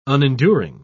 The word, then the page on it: unenduring: British English